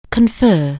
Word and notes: confer: anglais

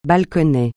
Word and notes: balconnet: francais

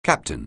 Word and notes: captain: anglais

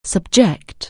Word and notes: subject: anglais

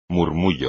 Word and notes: murmullo: espagnol

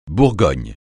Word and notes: bourgmestre: francais

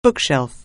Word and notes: bookshelf: anglais

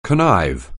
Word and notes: connive: anglais

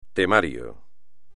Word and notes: temario: espagnol

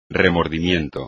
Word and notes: remordimiento: espagnol

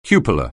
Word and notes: cupola: anglais

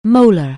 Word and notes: molar: anglais

